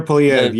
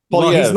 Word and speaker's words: Poilievre: Trudeau does bind Canada, can Poilievre, am I saying his name right? – Pierre Poilievre, yes. Poilievre.